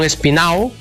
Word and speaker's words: Espinado: Corazón Espinao, bueno, Espinado se escribe, pero dicen Espinao.